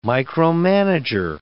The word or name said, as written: micromanager